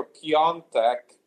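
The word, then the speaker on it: Piątek: So, of course, we have man of the season so far, Genoa striker Piontek, and that's the correct pronunciation of his name.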